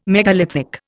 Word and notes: megalithic: Indian

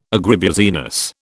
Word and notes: agribusiness: British English

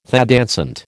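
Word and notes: thé dansant: American English

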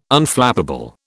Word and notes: Unflappable: British English

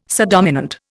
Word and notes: subdominant: Indian

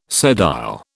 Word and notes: sedile: British English

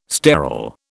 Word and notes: sterol: British English

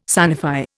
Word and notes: sanify: Indian

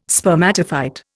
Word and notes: spermatophyte: Indian

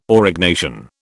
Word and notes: Aurignacian: British English

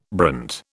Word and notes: brant: British English

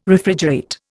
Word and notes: Refrigerate: Indian

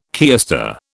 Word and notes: keister: British English

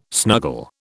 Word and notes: snuggle: British English

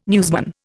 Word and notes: newsman: Indian

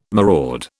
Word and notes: maraud: British English

